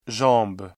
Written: jambe

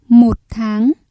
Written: một tháng

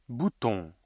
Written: bouton

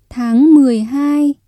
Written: tháng mười hai

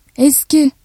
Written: eski

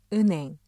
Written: 은행

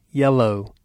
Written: yellow

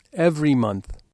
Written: every month

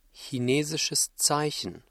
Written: chinesisches Zeichen

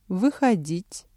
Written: выходить

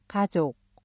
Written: 가족

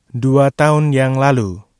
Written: dua tahun yang lalu

